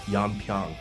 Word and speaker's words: Yeonpyeong: Yeonpyeong. – Perfect. – I have such a hard time pronouncing that. I always want to say Pyongyang.